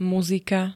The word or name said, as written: muzika